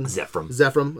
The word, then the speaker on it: Zefram: I do like Zefram Cochrane. Is that how you say his name? You said Zefram.